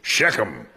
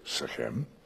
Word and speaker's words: Shechem: Now, typical American pronunciation is Shechem, because we put the emphasis on the first half of a word, a first syllable, where in Hebrew it's the second half for them. So they would say Shechem.